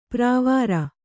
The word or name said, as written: ಪ್ರಾವಾರ